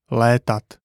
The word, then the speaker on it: létat: E is pronounced like the I in shed, but held for longer. An example is létat, meaning to fly.